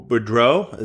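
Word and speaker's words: Boudreau: Manny had 484, and next is Lou Boudreaux. Is that how you say it? – You got it, King.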